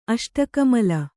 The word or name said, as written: ಅಷ್ಟಕಮಲ